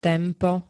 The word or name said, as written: tempo